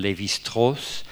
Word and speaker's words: Lévi-Strauss: Hi va haver un important investigador i teòric de l'antropologia, que és en francès, pronunciaran Claude Lévi-Strauss, que s'escriu Lévi-Strauss,